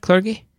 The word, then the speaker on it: clergy: Clergy? – Clergy. – That's the one. – Soft G, darling. Clergy rhymes with largy.